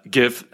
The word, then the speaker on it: GIF: Uh, GIF, as people may or may not know, stands for Graphics Interchange Format, and graphics has a hard G sound, so that should translate to the GIF acronym.